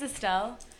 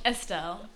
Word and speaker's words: Estelle: Okay, so my name is Estelle. – Oh, Estelle. It's Estelle? – Estelle. – Estelle. I feel like E or A. – It's E. But like people speak different languages. They pronounce it differently.